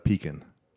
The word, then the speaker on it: pecan: How do we pronounce this word? – It's pecan.